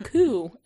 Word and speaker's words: coup: And she pulled it up on Google and was like, and had Google pronounce. It said, coup. And I was like, I believe you. I believe Google. But I've always heard it pronounced a coup. Like, it's a coup against the Capitol. – Always heard it incorrectly.